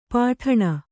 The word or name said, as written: ಪುಟಾಣಿ